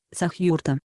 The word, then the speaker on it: Сахюрта: Правильное ударение – Сахюрта.